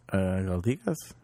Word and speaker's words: Galdikas: How should we say that last one? – Galdikas? – There we go.